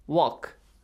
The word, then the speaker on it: walk: Walk. – Walk. W-A-L-K. – Are you sure? – Yes. – Okay, mashallah. So L is silent here as well.